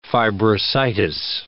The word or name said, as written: fibrositis